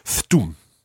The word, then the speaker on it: Thtoom: Thtum. That is a narrative part of this story. Cara arrived. Thtum. Okay, and if you wanted to spell that, it would be T-H-T-O-O-M.